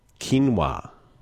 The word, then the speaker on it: quinoa: It's actually pronounced quinoa. Quinoa, not quinoa. Quinoa.